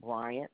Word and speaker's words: Bryant: The name is Carolyn, spelled with the K, K-A-R-O-L-Y-N. Last name, Bryant, B-R-Y-A-N-T.